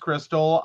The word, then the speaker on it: Krxstxl: K-R-X-S-T-X-L. She wants to name her daughter K-R-X-S-T-X-L. Confused, the name is pronounced Crystal.